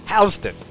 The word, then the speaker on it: Houston: Houston. – It's spelled like Houston. Houston.